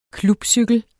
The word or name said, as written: klubcykel